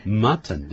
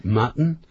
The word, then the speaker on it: mutton: 是的，如果是念慢，如果是正常念法 ,mutton, 对不对？ – 对。 – 他们念快的时间 ,mutton, 用鼻音出来，用 mutton, 羊肉。